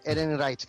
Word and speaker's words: Ehrenreich: Es un apellido alemán raro. Ehrenreich, creo que se pronuncia.